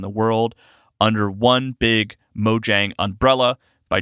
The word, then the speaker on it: Mojang: Minecraft developer Mojang, which I learned is pronounced Mojang in Sweden, where the studio is based, and then in America, it is pronounced Mojang.